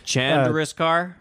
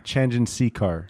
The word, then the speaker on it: Chandrasekhar: How do you pronounce Jay's last name? Chandriskar? – Chandrasekhar.